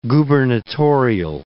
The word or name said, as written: gubernatorial